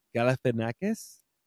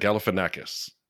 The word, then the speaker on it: Galifianakis: Galifianakis? – That's what I would have said, Galifianakis.